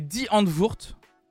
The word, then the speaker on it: Die Antwoord: alors je crois que la prononciation, c'est Die Handwurst. Moi j'ai toujours prononcé comme ça, en tout cas j'ai toujours entendu prononcer comme ça.